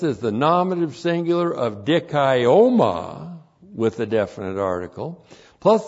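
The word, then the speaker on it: dikaioma (δικαίωμα): So here we have the new word in this word group of dikaioma. D-I-K-A-I long O-M-A. Dikaioma.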